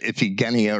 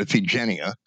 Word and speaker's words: Iphigenia: He sacrificed his daughter Iphigenia. Some people might pronounce it Iphigenia or Iphigenia.